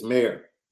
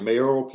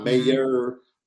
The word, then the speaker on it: Mayer: The correct pronunciation is Mayer, like mayoral candidate, not Mayer. Is Mayer. – Mayor.